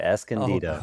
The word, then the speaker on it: Escondido: Did I mess up Escondido? Is that how you pronounce that? – That's exactly how you do it, Escondido.